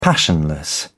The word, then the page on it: passionless: British English